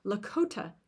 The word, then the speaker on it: Lakota: Let me say that correctly Lakota ceremonies in Pine Ridge Reservation.